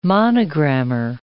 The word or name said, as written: monogrammer